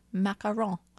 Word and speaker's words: macaron: And yes, that's how you pronounce it, macaron.